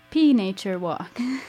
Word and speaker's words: Pnature Walk: Together, they play as Pnature Walk, but nature spelled with a P at the beginning, so like Pnature Walk. Welcome to silent. The show. – It's a silent P.